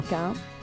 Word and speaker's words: Caïn: Caïn, merci.